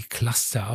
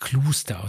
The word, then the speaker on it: Cluster: Ähm, die meisten sprechen die Cluster aus, aber ich musste mich mal eines Besseren belehren lassen. Die äh, wird Cluster ausgesprochen.